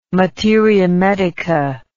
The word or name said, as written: materia medica